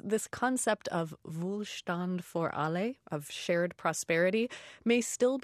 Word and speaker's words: Wohlstand für alle: Wohlstand für alle, which means material well-being for all.